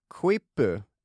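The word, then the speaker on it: quipu: Ordet kvippu, är det så vi väljer att uttala det? Vad tror du? – Ja, det tycker jag, och det betyder då knut.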